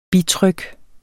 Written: bitryk

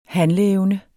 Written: handleevne

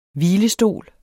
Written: hvilestol

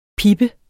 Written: pippe

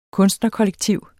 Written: kunstnerkollektiv

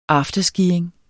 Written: afterskiing

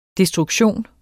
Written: destruktion

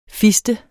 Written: fister

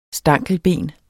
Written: STANKELBENENES

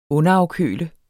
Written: underafkøle

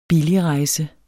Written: billigrejse

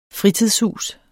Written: fritidshus

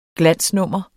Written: glansnummer

